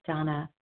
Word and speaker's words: Dana: This is Donna B., recovered compulsive overeater and bulimic, calling from Denver. And I think at this point, many of you know that my name is spelled D-A-N-A, although pronounced Donna.